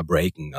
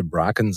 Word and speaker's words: Bracken: Und ich habe immer Breaken, also ich habe quasi so ein bisschen die amerikanische Version genommen und es sollte aber eher so das englische Bracken sein und ich habe Breaken ausgesprochen und die Autorin war halt dann not amused, wie man so sagt.